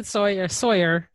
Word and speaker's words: Tsoir: It's actually Tsoir. So the T is sort of silent.